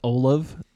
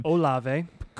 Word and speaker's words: Olave: The Saints have Chris Olave, which is how I pronounce his name. – Olave.